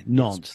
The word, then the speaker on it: Nantes: I always pronounce that one Nantes. incorrectly. Nantes. Thank you.